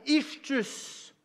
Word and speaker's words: ichthus: ichthus.